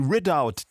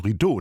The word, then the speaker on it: Ridout: Aufgenommen hat sie Timothy Ridout, der englische Bratscher. Habe ihn längere Zeit falsch ausgesprochen. Ich dachte, man spreche das Französisch. Rideau, nein, Ridout, very British.